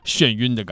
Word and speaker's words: 眩晕: 这儿其实应该读作是头晕的感觉和啊眩晕的感觉。